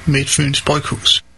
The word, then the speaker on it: Midtfyns Bryghus: Hi, in Denmark we pronounce Midtfyns Bryghus as Midtfyns Bryghus.